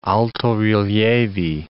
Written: alto-rilievi

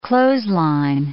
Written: clothesline

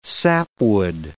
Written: sapwood

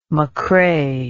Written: mccrae

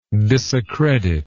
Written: disaccreditation

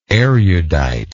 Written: eruditeness

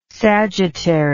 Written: sagittary

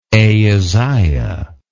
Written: ahaziah

